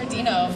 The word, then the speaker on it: Deno: I think it's just Hunter Deno for me, D-E-N-O. And yes, that's how it's pronounced, not Deno. – It's just Jordon Fite, just my name, J-O-R-D-O-N, Fite, F-I-T-E, or J-T Fite, same thing.